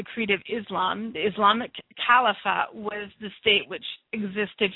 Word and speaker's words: Khilafah: It is more well known in English texts as the Caliphate, but the actual Arabic term is Caliphate, which is spelled K-H-I-L-A-F-A-H.